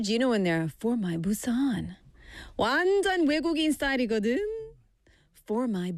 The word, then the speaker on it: Busan: We don't say 부산. Busan, short A. In Busan, we say Busan.